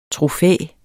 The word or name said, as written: trofæ